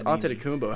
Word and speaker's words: Antetokounmpo: It's Antetokounmpo.